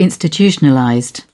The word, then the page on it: institutionalized: British English